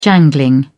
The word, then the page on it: jangling: British English